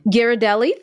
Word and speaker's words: Ghirardelli: A Mars Wrigley Confectionery, B Ferrero Group, C, I should know how to say this, but Ghirardelli. Ghirardelli. Ghirardelli.